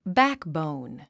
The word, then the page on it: backbone: bǽkbòun; バクボーン